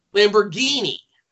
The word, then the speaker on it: Lamborghini: Okay, so it's pronounced Lamborghini? – Lamborghini.